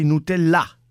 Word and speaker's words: Nutella: Una frase a Canal Plus si candida per la bufala dell'anno: non bisogna mangiare Nutella, anzi Nutella, con l'accento sull'A, come dice il francese.